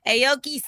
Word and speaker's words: Aoki: Aoki Simmons.